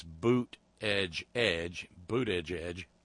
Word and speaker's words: Buttigieg: Let me help you with the pronunciation. You could use the graphic that they put up on his announcement video that says boot edge edge, or being as how we know how E.W.